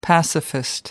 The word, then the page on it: pacifist: American English